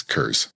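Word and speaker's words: Curze: Conrad Cruz. Come on. – Which, it's Kurz.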